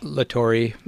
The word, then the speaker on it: Latore: Does he pronounce it that way? – Latore. – Latore. – Yeah. – Oh, well, a little Americanized.